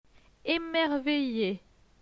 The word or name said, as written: Emerveille